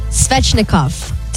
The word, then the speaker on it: Svechnikov: Andre Svechnikov.